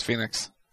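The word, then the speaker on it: Fénix: No, it's Phoenix.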